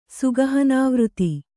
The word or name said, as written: ಸುಗಹನಾವೃತಿ